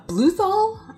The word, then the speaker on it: Bluthal: Uh, there was also John uh, Bluthal, I, I want to say how you say his name.